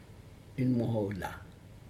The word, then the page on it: inmohoc la: Aneityum